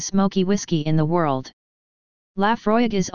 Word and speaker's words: Laphroaig: Laphroaig, pronounced Laphroaig, is located on the southern coast of the island.